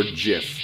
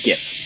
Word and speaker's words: GIF: Is it pronounced GIF or JIF? – GIF.